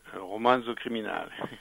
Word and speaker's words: Romanzo Criminale: Puisque là, c'est pour un petit peu honorer Romanzo Criminale. Je suis sûre que je prononce très mal. – Romanzo Criminale. – Ah oui, c'est beaucoup mieux comme ça.